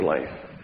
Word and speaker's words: Leif: And so as you introduced Leif, is it Leif or Leif? Leif. How do you spell it? L-E-I-F.